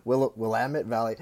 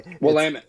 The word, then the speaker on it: Willamette: Willamette Valley. – Willamette.